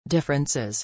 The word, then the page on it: differences: i